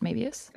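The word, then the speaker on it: Möbius: which is that how you say it, Mebius? – That's exactly how you say it.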